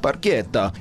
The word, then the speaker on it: barchetta: Something I learned about the song is the word barchetta, actually pronounced barchetta, is an Italian word meaning small boat.